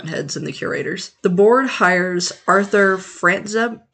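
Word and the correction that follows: Frantzreb: No, hang on, I didn't say that right. Frantzereb. It's Frantzereb.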